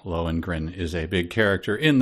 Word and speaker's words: Lohengrin: Lohengrin, is that how you pronounce that? – Yeah.